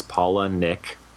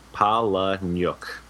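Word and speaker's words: Palahniuk: the pronunciation is Paula Nick. Like Paula and Nick.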